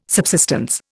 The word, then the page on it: subsistence: Indian